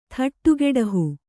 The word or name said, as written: ಥಟ್ಟುಗೆಡಹು